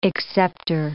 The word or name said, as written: acceptor